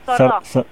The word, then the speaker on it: Sourdeaux: cómo se pronuncia, Sordox, Sordox. – Sordeaux. – Sordeaux. Ah, como Deraux, de, como Dero. – yo, Ah, Sordao se escribe, perdón, Adolfo Sordá. – Sordó. sordó. – Ahí, estás, Sordó, bien. Contanos un poquito cómo es eh, eh, la vida en la localidad, eh, cómo, es la vida cotidiana ahí.